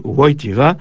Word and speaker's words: Wojtyła: résolu quand on apprend que le L barré propre à l'écriture polonaise se prononce WE, Karol Wojtyła, ou l'air Wałęsa, ou encore la ville de Łódź, Lodz à la française, patrie du grand pianiste Arthur Rubinstein.